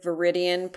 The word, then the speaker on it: Viridian: I'm Viridian Prep, and that's V-E-R-I-D-I-A-N, rhymes with Meridian, but with V, like Victory.